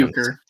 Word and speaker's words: Bucher: I don't know if many people are familiar with Rick Boucher. I don't really know how he's Bucher. – It's Bucher. There you go. – That's how you pronounce it.